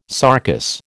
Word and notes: sarcous: American English

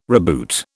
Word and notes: reboot: British English

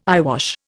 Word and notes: eyewash: Indian

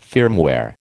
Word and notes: firmware: American English

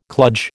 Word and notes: kludge: American English

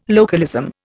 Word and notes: localism: Indian

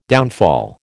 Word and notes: downfall: American English

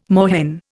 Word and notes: moorhen: Indian

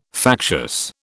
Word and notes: factious: British English

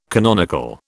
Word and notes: canonical: British English

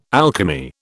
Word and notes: alchemy: British English

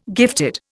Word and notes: gifted: Indian

